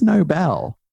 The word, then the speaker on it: Nobel: And I pronounced the whole thing like the Nobel Prize. And then someone was like, I think it's Nobel. Yeah. And it is because it's named after Alfred Nobel.